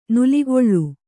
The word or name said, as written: ನೆಲೆಕೊಳ್